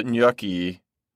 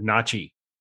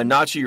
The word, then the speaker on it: gnocchi: I used to have a friend who used to pronounce it gnocchi. And I thought, gnocchi. It's not gnocchi. So I got some gnocchi ragu.